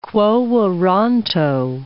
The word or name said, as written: quo warranto